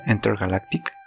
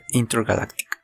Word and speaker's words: Intergalactic: Una película que en el episodio anterior me quedó pendiente que esto, y estoy hablando de Intergalactic la pronunciaba mal el episodio anterior, le decía Intergalactic, porque así se escribe, pero eh, pues se pronuncia Intergalactic.